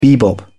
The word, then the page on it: bebop: British English